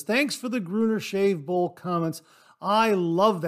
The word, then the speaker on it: Gruner: But Gary, thanks very much for confirming that I was pronouncing the name Gruner correctly.